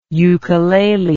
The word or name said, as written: ukulele